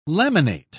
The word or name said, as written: laminate